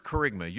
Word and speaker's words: kerygma: That word kerygma, you're trying to write it down, right? And you didn't spell it right? Here's how you spell it. K-E-R-Y-G-M-A. K-E-R-Y-G-M-A. Kind of sounds like a coffee maker, doesn't it?